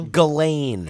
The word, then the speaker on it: Ghislaine: Jesleen Maxwell? – Ghislaine. – Ghislaine. – God. – Jesleen.